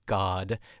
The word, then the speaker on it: Gad: Like one time when we went to Italy for Keon's cousin's wedding, we met this beautiful Lebanese man who was there, and his name was God, but it's spelled G-A-D.